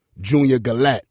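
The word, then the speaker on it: Junior Galette: We're here with Port-au-Prince's own Junior Gallette.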